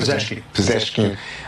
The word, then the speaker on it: Pezeshkian: Yeah, so I think that what we saw from Mr. Pezeshkian, did I pronounce that correctly? – Pezeshkian.